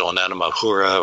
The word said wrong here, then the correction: Oumuamua: or Oumuamua.